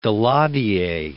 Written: Daladier

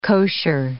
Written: kosher

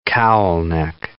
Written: cowl-neck

